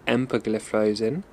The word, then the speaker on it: empagliflozin: The first is dapagliflozin, the second is canagliflozin, and the third is empagliflozin.